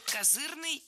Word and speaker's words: козырный: В этом слове двойная норма ударения, и возможны оба варианта. С ударением на втором слоге и окончанием «ый» – козырный, и с ударным окончанием «ой» – козырной. И сленг здесь ни при чем, оба слова литературные.